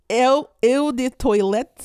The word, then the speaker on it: Eau de toilette: It says it's spelled toilet, but it's not spelled. It's not. You don't say it toilet, but it's like. – How do you say it? Stole? – Yeah, some fancy. Fancy. Oh, look. – Toilet? – Eau de toilette spray.